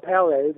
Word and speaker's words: Palais: Bob Palais, that's how it's pronounced, like a French palace.